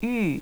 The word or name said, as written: yu